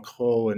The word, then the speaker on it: Cros: Cros. That's Cros. Some people mistakenly hear Cheryl Crow, and very different story.